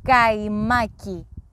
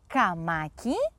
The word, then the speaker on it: καϊμάκι: Πρόσεξε μόνο να προφέρεις σωστά τη λέξη καϊμάκι όταν πας στο Βασίλη αύριο. Μην πεις θέλω καμάκι, γιατί θα προτείνει να συναντηθείτε αύριο στην παραλία κάτω από την Πανσέλινο».